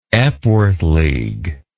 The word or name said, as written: epworth league